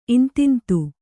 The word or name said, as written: ಇಂತಿಂತು